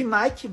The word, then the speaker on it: Nike: Se escreve Nike, mas se lê Nike.